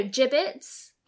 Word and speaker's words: Jibbitz: Okay, let's talk about giblets. No, gibbets. I always say it wrong. I'm going to spell it out for everyone. Okay, they are... – Giblets. – They are J-I-B-B-I-T-Z. So that's gibbets.